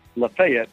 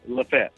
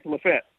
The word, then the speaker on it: Lafayette: And by the way, since I've been here, you and I thought it was pronounced Lafayette. It is not. It's Lafette. – Stop it. – We've been saying it wrong. – Dude, come on. Lafette? – That's what the locals tell me, Lafette.